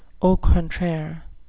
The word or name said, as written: au contraire